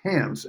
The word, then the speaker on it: Hans: And Hans Olsen in Phoenix, that's Hans, H-A-N-S, It rhymes with chance, he says.